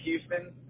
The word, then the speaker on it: Houston: Houston, and then it's spelled just like it says, H-O-U-S-T-O-N.